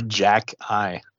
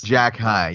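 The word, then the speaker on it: Xhekaj: And I asked you before we started how to pronounce his last name. What's his Arbor? – Arbor Jack High. – It's Jack High. Yeah, Jack High.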